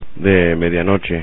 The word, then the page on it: de medianoche: spanish